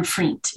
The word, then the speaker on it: Vriend: It's like friend with a V. – Yeah, that's what I figured. I was like, maybe it is, maybe it's not though. But well, anyway. The official pronunciation is Ann Vriend. It's a Dutch name.